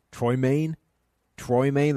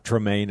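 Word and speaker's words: Troymaine: They even got Troy Maine. That's really a name. It's not Tremaine. It's Troy Maine.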